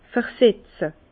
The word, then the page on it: versetze: Bas-Rhin; Herrlisheim